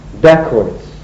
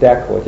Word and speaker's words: dacoit: The original was dacoit. And the English pronounced it dacoits.